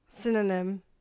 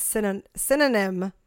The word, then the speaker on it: synonym: Synonym. – Yes. – It sounds like cinnamon, but it's synonym.